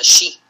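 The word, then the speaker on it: shi (四): Y esto es porque en japonés el número 4 se pronuncia shi. Y la palabra muerte en japonés también se pronuncia shi.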